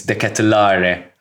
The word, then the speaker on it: De Ketelaere: But speaking of dangerous players, when Milan made those substitutions, one man that significantly fucking stood out is Charles De Ketelaere, by the way. – That's how you say it. – It's De Ketelaere, apparently.